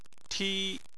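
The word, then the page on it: ti: tone 1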